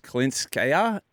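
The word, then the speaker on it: Kalinskaya: We're so slowly seeing some of these fairy tale stories peter away after Zhang won that match yesterday against Klinskaya.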